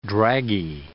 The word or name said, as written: draggy